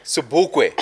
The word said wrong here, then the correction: Sobukwe: King. – Sobukwe.